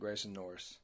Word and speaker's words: Grayson Norris: That is correct, Grayson Norris.